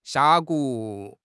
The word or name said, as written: 峡谷